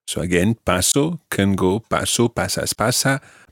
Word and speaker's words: paso: Yeah, just always remember that the stress is on the first syllable in parts 1, 2, 3 and 6. Paso, pasas, pasa and pasan at the end.